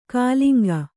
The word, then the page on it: ಕಲಿಂಗ: kaliŋga